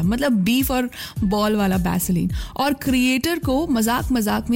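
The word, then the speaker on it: Vaseline: Korea में beauty creators मिंडे अली जो है वो अपनी वैसलीन कलेक्शन दिखा रही थी, लेकिन प्रोनाउंसिएशन की वजह से वैसलीन सुनाई दिया बैसलीन, और इंटरनेट ने कहा कि बस अब तो बैसलीन ही है।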